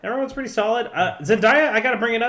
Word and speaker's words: Zendaya: and it's pronounced Zendaya. Yeah. – Zendaya.